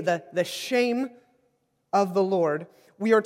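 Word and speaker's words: shame (שם): The second word for name is, it's spelled like Shem, but it's pronounced shame. Can you say shame? Shame sounds like just the English shame, but has no connection whatsoever, so don't think about that too much.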